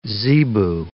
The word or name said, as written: zebu